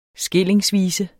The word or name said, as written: skillingsvise